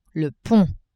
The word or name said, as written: pont